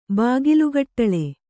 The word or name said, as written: ಬಾಗಿಲುಗಟ್ಟಳೆ